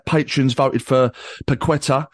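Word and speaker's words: Paquetá: He's named after that area, and that area is pronounced Paqueta.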